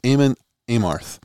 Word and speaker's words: Amon Amarth: They're touring with a band with a, with a great band called Amon Armarth. I God, I'm saying that wrong. It's Amon Amarth, um, and former Arizona State head coach Tate Green busted my balls one day.